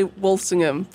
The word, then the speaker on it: Walsingham: Yeah, we say Walsingham, but Walsingham, Americans would say Walsingham.